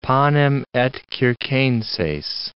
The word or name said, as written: panem et circenses